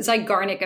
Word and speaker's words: Zeigarnik: I believe it's the Ziegernilk. Am I pronouncing that right? The Ziegernilk effect. – The, the Zeigarnik effect.